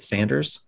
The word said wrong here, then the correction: Saunders: Saunders? I think it's Saunders. It's Saunders.